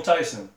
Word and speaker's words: Tysen: It's Daniel Tysen.